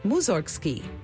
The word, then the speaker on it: Mussorgsky: In Russian, his name is pronounced Mussorgsky, which sounds like it has something to do with cows, but it doesn't.